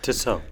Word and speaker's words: Tissot: So I went to the web and I Googled, how do you pronounce T-I-S-S-O-T?